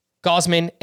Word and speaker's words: Gausman: Sandy Gausman and Joe Musgrove.